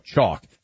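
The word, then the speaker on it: CoQ: Chalk. That's C-O-Q spelled with a Q.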